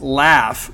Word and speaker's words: LAF: But it's our boy Ritz, and we're going to go with L-A-F. He's pronounced it as laugh, but it's lame as you know what.